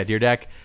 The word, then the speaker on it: Dyrdek: Dyrdek? – Yeah, Dyrdek.